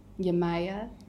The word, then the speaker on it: Yemaya: And just to paint people a picture, we're sitting in a dark room because we decided to cover everything for Yemaya. Am I saying it right? – Yes, you are.